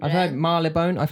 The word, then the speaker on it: Marylebone: Mar but it's spelled like Mary LeBone.